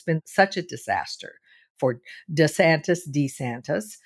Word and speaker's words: DeSantis: Apparently, he has gone back to the original pronunciation of his name, which is DeSantis, not DeSantis, DeSantis.